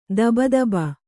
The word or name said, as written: ಧಬಧಬೆ